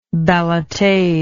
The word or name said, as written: ballotade